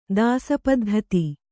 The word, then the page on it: ದಾಸಪದ್ಧತಿ: dāsa paddhati